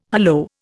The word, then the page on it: hallo: Indian